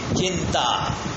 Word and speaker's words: chinta: C-H-I-T for Tom. T-A. Chitta. Chitta. Chitta is that cremation fire. And then there is another word with just one nasal sound in between, chinta, and N in between, chinta.